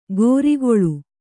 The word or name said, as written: ಗೋರಿಗೊಳು